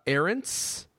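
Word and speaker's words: Ahrendts: Angela Arendts? – Yes.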